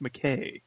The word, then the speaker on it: McCaa: But uh, you know, last week we had Mr. Jeremy McCaw, McCaw, M C C I don't know, M C C A A. McKay though, pronounced McKay. McKay, that's right, McKay.